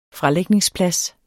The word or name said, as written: fralægningsplads